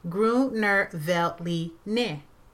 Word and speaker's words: Gruner Veltliner: B-R-U-T is pronounced as Brut. Chianti is actually Chianti with the K. Gruner Veltliner. The correct pronunciation is Gruner Veltliner.